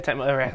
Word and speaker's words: Tecmo: Yeah, Tecmo. That's how you say it.